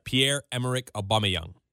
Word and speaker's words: Pierre-Emerick Aubameyang: Pierre-Emerick Aubameyang.